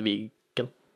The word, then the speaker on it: Viken: Ja, det blir uttalt Viken. – Ja. Jeg tror det er valgfritt. – Sånn som i slutten av uka. Viken.